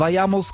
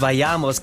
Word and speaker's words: vayamos: Marques, vayamos, compañeros. – Ja, du hast das ein bisschen falsch ausgesprochen. Marques mit vayamos, compañeros. Vayamos, compañeros.